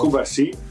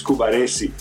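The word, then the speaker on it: Cubarsí: But by the way, Ali, if I can correct you about the Barcelona center backs, the pronunciation of his name, it's not Kubarsi, it's Kubaresi when he plays like this.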